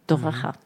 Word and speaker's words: Toraja: que se dice Toraja.